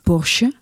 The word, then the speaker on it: Porsche: Je zegt Porsche, hè? – Porsche, ja. Porsche.